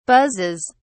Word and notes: buzzes: British English

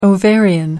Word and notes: ovarian: American English

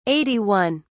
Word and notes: 81: British English

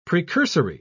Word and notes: precursory: British English

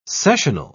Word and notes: sessional: British English

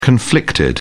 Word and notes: conflicted: British English

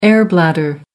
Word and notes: air bladder: American English

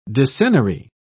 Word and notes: decennary: British English

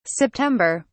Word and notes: september: British English

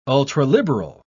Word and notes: ultraliberal: British English

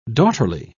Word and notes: daughterly: British English

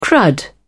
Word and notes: crud: British English

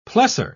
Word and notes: plessor: British English

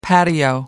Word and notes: patio: American English

